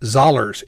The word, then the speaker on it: Zollers: Zollers. It's it's Zollers rhymes with dollars. He told me that.